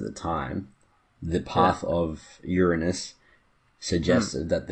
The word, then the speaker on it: Uranus: The last planet they knew was Uranus. – Yeah. – Is that how it's pronounced? – Yeah, I believe that's the perfectly accepted version of the pronunciation.